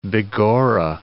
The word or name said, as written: begorra